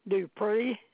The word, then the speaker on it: Dupree: Marilyn, M-A-R. I-L-Y-N. – Thank you. And your last name? – Dupree. D-U-P-R-E-E.